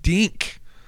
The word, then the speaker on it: Dink: So for more information on Dink, you got to say it right, Dink, Dink, D-I-N-K.